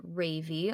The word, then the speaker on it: Rayvi: And she, R-A-Y-V-I. Yes, that's how you pronounce it.